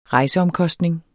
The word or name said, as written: rejseomkostning